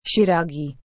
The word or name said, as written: shiragi